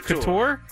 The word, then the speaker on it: Couture: Randy Couture, John Couture? – Jones. – Wait, Couture? – Couture? I don't. Is Couture. It Couture? – It's Couture.